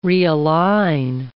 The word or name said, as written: realign